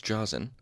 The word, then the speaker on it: Josn: Okay, it's spelled J-O-S-N, so you can forgive me a little bit, my pronunciation. Okay, his name was Josen, and he had paid Roant for passage to Annalyn.